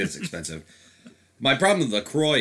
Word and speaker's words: LaCroix: And then it's Cran Raz Seltzer, which is, I call it LaCroix. I don't know if that's the proper way to say that. That is the proper way to say it. It is LaCroix.